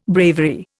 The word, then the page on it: bravery: Indian